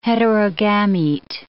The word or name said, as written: heterogamete